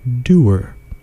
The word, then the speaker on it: dour: That is because, apparently, more than 60% of Americans pronounce the word doer, rhyming with tour.